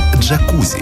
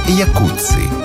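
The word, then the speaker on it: Jacuzzi: Это и есть то, что мы называем словом «джакузи». Хотя правильнее было бы сказать «якутси». Именно так в действительности произносится фамилия тех самых братьев-итальянцев.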